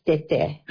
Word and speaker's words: Tete: It is the Tewa word for grandfather. – Yeah. – And he says right. – It's pronounced Tete.